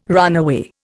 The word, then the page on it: runaway: Indian